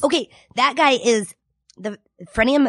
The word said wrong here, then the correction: frenum: Am I saying that right? Frenulum? – Frenum. – Frenum. Okay, the dude.